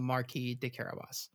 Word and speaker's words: Marquis de Carabas: Yeah, but in the master class from Neil Gaiman, he calls him the Marquis de Carabas. – All right, because that's how it's pronounced.